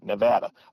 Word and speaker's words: Nevada: I mean, how would we find people in California or Nevada or, notice I did say it right too, Nevada.